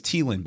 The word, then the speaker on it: Thielen: He's like, actually, by the way, it's Thielen. By like, and, and on the pronunciation guide, T E E capital T E E dash Lin L E N wow. lowercase n Thielen. Wow. And he's like, that's actually how you say it.